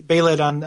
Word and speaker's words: Behelit: Am I pronouncing that right? I say like Behelit. Behelit? – Okay. They say Behelit on both the animated forms I've seen. – Okay, so I will defer to that.